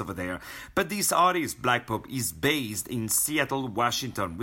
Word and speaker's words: Blak: Blak. Let me spell it for you. Blak is spelled without the C, B-L-A-K, Pope, P-O-P-E.